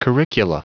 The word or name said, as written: curricula